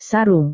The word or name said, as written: sarung